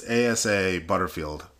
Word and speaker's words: Asa Butterfield: Asha something? – It's Asa Butterfield. – Asa Butterfield, Asa, yeah.